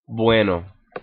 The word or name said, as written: bueno